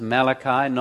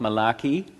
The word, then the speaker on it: Malachi: This is the last book of the Old Testament, Malachi, and it's pronounced Malachi, not Malachi, Malachi, and it's not a very nice book.